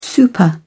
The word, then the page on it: super: British English